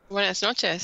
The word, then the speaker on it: Buenas noches: Buenas noches, señoras y señores. Did I say that right? – Buenas noches, sí. Very good.